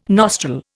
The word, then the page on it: nostril: Indian